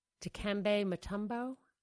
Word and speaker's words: Dikembe Mutombo: Well, it reminds me of when Dikembe Mutombo.